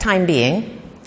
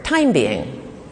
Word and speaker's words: time being: So if you put the emphasis on the second word, time being, it means temporarily or for now, which is the normal usage of the word. But if you place the emphasis on the first word, time being, right, it suggests a being, like a human being or an alien being, right? In other words, an entity made out of time.